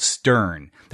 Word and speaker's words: Steorn: Company based out of Dublin, Ireland, called Stern. That's spelled S-T-E-O-R-N. The O is silent, at least in our language.